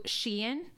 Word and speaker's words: Shein: Shein, is that how you say it? – Yeah.